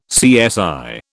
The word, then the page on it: CSI: British English